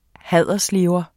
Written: haderslever

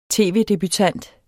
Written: tv-debutant

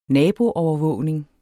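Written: naboland

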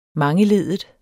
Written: mangeleddet

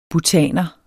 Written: bhutaner

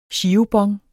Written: girobon

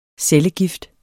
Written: cellegift

